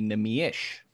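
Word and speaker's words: Neemias: I asked a Utah State assistant after Nimi got drafted, like, how do I pronounce his name? And he told me Nimi-ish, right? – That's interesting.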